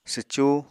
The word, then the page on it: swcú: apatani